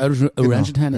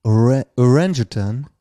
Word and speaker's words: Orangutan: Aber es ist krass, wie das wird ja gleich geschrieben, nur komplett anders ausgesprochen. – Orangutan, so sagen die. – Orangutan. – Und du sagst, ich stand da wie so ein deutscher Honk und meinte Orang-Utan.